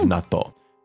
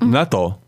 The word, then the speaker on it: natto: But the first of all, what is the pronunciation of natto? – Natto. Natto. So it's including the small ts. – So it's not natto. – No, natto. – And it's a long O at the end. So natto. – Natto.